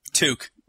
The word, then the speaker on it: toque: It says toque, pronounced like puke. – Pronounced. – Yeah, pretty much. Toque. – It's pronounced like puke, but with a T.